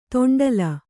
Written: ದಂಡ್ಲೆ

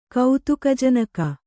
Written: ಕೌತುಕಜನಕ

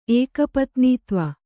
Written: ಏಕಪತ್ನೀತ್ವ

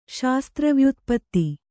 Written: ಶಾಸ್ತ್ರವ್ಯುತ್ಪತ್ತಿ

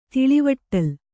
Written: ತಿಳಿವಟ್ಟಲ್

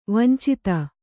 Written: ವಾಂಛಿತ

